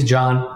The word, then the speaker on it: Can: So my name is John, it's spelled C-A-N.